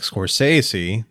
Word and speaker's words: Scorsese: You've got somebody like Scorsese.